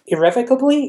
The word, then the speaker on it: irrevocably: irrevocably. Is that how you pronounce it? Yes.